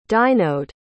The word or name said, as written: dynode